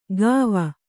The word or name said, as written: ಗಾವ